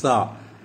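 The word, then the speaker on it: sa (שר): So when you look at this Hebrew word for prince, it's pronounced sa, meaning it ruler, chief, commander, or governor, right?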